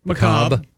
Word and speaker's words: macabre: Macabre. Yeah, the R-E silent or whatever.